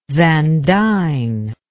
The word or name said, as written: van dine